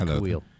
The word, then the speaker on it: Kuiil: Let's talk about dead Kweel. – Kweel? – Kweel? – Kweel? – Kweel? – Kweel? – Kweel? – Kweel? Kweel? – Is there a silent Q? – It's actually K-U-I-I-L.